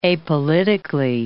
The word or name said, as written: apolitically